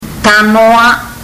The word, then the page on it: tanoa: fiji